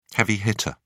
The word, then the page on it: heavy hitter: British English